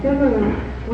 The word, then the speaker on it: Sumeru: And Sumeru, we usually just hear Shumi, the full name is Sumeru. So there's an extra syllable in Chinese that we don't always see.